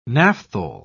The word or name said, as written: naphthol